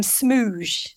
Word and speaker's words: Smooge: Smooge. – Oh, yes. – Pronounced as in Bruges.